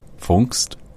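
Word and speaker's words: Pfungst: Am I even close to saying that right? – Fungst. – Fungst. – It's like you got to do the P and the F.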